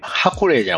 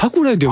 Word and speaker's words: ハクライ: これ、ハクレイじゃなくて、ハクライと読むみたいですね。 – ハクライと読むんですか。ハクライね。